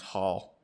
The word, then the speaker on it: Hall: Hole with holding. – Well, it's actually pronounced Hall. – Whatever. – Like a soft O.